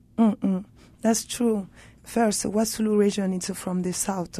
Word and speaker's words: Wassoulou: So you were in Paris now, but your family is from the Wassoulou, am I saying that right? Wassoulou region of Mali? – Exactly, yeah.